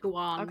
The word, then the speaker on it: Huan: is on the A, so we would pronounce the A specifically, Huan.